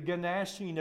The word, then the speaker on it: gnashing: You're the, as I say to my students, the gnashing of teeth. And they say, do you not? Yeah, I know that's a silent G.